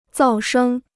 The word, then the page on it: 噪声: zào shēng